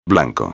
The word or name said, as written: blanco